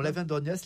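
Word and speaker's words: Aven d'Orgnac: On continue nos pérégrinations souterraines à Lavendorgnac, c'est comme ça qu'on prononce ? – Absolument, Lavendorgnac, là on est dans le sud du département,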